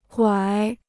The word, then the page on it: 拐: guǎi